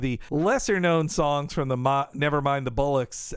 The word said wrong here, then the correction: Bollocks: All right, look, I probably mispronounced Bollocks. – Bollocks, the probably. Bollocks.